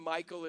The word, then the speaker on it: Micheal: My name, Michael, is not M-I-C-H-A-E-L, but my name is actually Michelle. M-I-C-H-E-A-L.